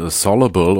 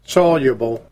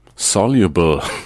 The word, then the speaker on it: soluble: Ich habe übrigens rausgekriegt, wie man das Wort soluble oder soluble ausspricht. Nämlich ist beides falsch. Eigentlich heißt es soluble. – Soluble. Ja, moderne Technik macht es möglich, dass auch ich das jetzt richtig aussprechen kann.